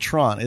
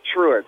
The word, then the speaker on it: Truett: I call it Tron. It's not Tron, is it? – No, it's like literally like Truant, like Jesus. – A Truant officer. – I've been fucking calling it Trant for like 2 fucking years on the air on Underground Horror Radio with Nicole.